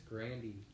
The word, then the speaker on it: Grande: Pronounce her name right. It's Grandy.